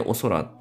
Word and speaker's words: お宇宙: この番組のタイトルもね、あの、ゲイの週末は一旦お空へ帰りますってお空っていう字は、あの普通のうかむりの空じゃなくて、おに宇宙って書いてお空と読む